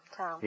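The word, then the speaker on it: Thom: Tom. – The H is silent, eh? – That's right. – Like in herring.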